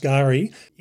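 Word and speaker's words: K'gari: And Gari, and it's spelt Kigari, but it's pronounced Gari, is well known for being very sandy sort of island.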